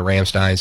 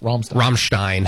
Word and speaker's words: Ramstein: Friends of ours my parents in the Air Force were stationed in Ramstein, so we spent like a Christmas up there with them. – I think it's pronounced Ramstein. – Ramstein.